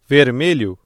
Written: vermelho

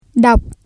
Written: đọc

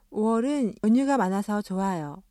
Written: 오월

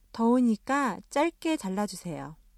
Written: 자르다

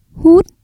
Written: hút

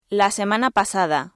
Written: la semana pasada

